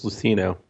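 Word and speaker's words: Luceno: Rogue One Catalyst is going to be written by another newcomer, James Lucano. I don't know. Can you pronounce this? – James Lucino.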